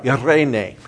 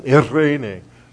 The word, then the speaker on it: eirene: You're supposed to roll the R, irene. – Irene. – Okay, well, absence of the, you know, tongue roll, irene. And it's spelled, in the English, it's spelled E-I-R-E-N-E.